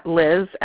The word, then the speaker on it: Liz: It's so it's just my first name, Liz, L-I-Z, and then Folt, F.